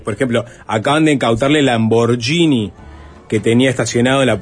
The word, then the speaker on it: Lamborghini: No, no, me parece que le das la pronunciación correcta, suntuosa, lujosa. Lamborghini. Exacto.